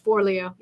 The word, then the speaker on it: Forleo: Forleo.